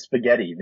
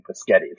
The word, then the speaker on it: spaghetti: They're not curious about how to pronounce spaghetti. They're very clear that they're right saying paschetti.